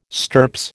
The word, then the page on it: stirps: American English